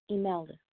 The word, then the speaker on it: Imelda: It's spelled I M as in mom, E L D as in David, A, Imelda.